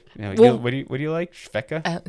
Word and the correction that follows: Svedka: Svedka. – Svedka. – Yeah. – Sorry, Svedka. I didn't say it right.